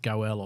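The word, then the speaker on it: goel (גֹּאֵל): That is pronounced goel, or that's probably, the English transliteration of it.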